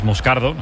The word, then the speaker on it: Moscardo: Como bien ha pronunciado Unai es Moscardo, brasileño.